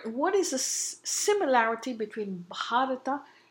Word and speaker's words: Bharata: Bharata comes from three syllables, Bharata.